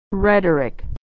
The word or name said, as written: rhetoric